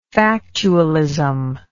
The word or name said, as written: factualistic